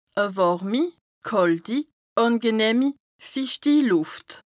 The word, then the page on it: e wàrmi / kàlti / àngenëhmi / fichti Lùft: Bas-Rhin; Strasbourg